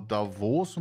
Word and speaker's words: Davos: Ich glaube, ausgesprochen wird es aber Davos und das ist das Weltwirtschaftsforum.